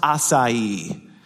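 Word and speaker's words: açaí: but it's pronounced acai. Acai. Did you know this? This is weird to me. I find that pretentious. Not my friend. My friend's great. I know him well. I find the berry pretentious. The whole berry, like the whole fruit. I'm done with the fruit. Acai. Acai's a pretentious fruit. You can't be four letters long and have three syllables.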